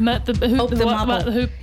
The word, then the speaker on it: Mott the Hoople: Mott the Hoople? – Yep. And I always feel like I'd say that wrong if I said it out loud. – Yeah. – Mott the Hoople. – Mott the Hoople.